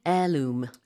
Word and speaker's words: heirloom: What's a family heirloom? – Heirloom. Interesting spelling because the H is silent and heirloom is H-E-I-R-L-O-O-M. – So we never say heirloom. – No, you pronounce it heirloom.